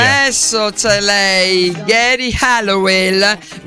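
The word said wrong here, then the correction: Geri Halliwell: Non so se la mia pronuncia va bene, ma penso Jerry Halliwell.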